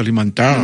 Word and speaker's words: Limantour: Que le tengo una pregunta, pero después de atender una llamada, acerca del apellido Limantour, ¿cómo se pronuncia Limantour? – Limantour, está bien. – Limantour. Ah, bien, que es de origen francés.